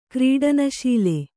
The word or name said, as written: ಕ್ರೀಡನಶೀಲೆ